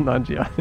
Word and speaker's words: Nanjiani: Kumail Nanjiani.